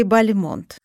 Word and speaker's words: Бальмонт: Правильное ударение – Бальмонт. Ну, хотя и Бальмонт, и Бальмонт, возможно. Почему? Да все очень просто. Когда Бальмонт уехал во Францию, решил называть себя на французский манер.